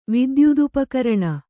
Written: ವಿದ್ಯುದುಪಕರಣ

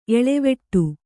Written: ಎಲೆವಡೆ

